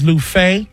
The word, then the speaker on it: Luffeigh: Last name is Lufay. L-U-F-F-E-I-G-H. And one F is silent.